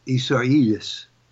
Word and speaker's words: Isaias: Now, we have Hurricane Isaias.